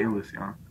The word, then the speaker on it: Ilusión: It's spelled Illusion, but they say Ilusión.